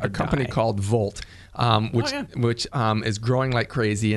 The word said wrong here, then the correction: Voalte: It's pronounced Voalte.